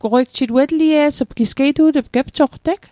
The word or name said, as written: goqwei ugjit